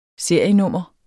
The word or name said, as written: serienummer